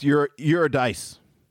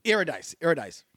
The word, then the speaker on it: Eurydice: Orpheus and is it Eurydice? I never knew how to pronounce the woman's name. – I think it's Eurydice. – Eurydice. Mm-hmm.